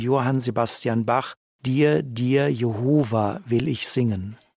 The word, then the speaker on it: Jehova: Als Zeugen Jehovas bevorzugen wir jetzt die Aussprache Jehova.